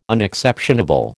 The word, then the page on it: Unexceptionable: American English